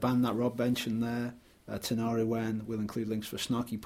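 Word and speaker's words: Tinariwen: Yeah, Tenariwen. – Let's spell it. T-I-N-A-R-I, Tenariwen, W-E-N. – Correct, yes.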